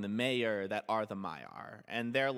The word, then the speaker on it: Maiar: Did you say the mayor? – Yeah, basically the mayor. – Manway? – Manway. The most powerful of the... – No, I gotta correct a pronunciation. That's the Maiar you're talking about.